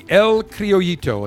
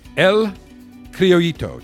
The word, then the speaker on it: El Criollito: The El Criollito, and that's spelled E-L and then C-R-I-O-L-L-I-T-O in Spanish, double L's, E. El Criollito, just like chicken. It's not polo, it's pollo.